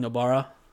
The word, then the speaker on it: Nobara: How do you say it one more time? – Well, we haven't had, you know, we record two episodes at a time, so we haven't had our pronunciation lesson yet. But at the moment, I was going Nobara.